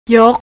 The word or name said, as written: yok